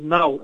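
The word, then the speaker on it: NAO: But these newer devices, like NAIOs, it is pronounced as NOW, as in N-O-W.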